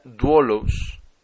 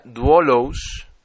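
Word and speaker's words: duolos (δοῦλος): La palabra se pronuncia duolos, primero duo, D-O-U, y la siguiente letra aparece una Y invertida, llamada lambda. Se trata de una I y termina en os, O-S. Se pronuncia duolos y significa esclavo o sirviente.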